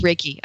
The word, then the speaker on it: Reiki: Is that a way that they folded the energy healing into the whole Reiki thing? – I mean, that's a lot of massage therapists just pick up Reiki as an add-on. – It's Reiki.